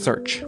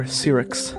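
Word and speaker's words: SearX: Sirix, or Search, I think it's probably pronounced Search, is an open source search engine that searches all the other search engines the same time.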